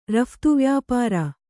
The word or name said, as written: ರಫ್ತುವ್ಯಾಪಾರ